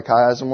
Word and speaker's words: chiasm: It's pronounced chiasm. That's how we say it. You guys remember what it was? C-H-I-A-S-M.